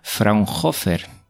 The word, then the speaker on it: Fraunhofer: Este formato nace de un instituto, a ver si lo digo bien porque es alemán, Fraunhofer, sí, lo he dicho muy bien.